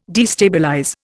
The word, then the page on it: destabilize: Indian